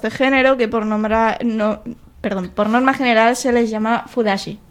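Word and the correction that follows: fudanshi: Fudansi. – Fudansi, perdón, lo pronuncio mal.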